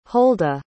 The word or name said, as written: holda